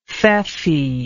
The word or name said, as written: feoffeeship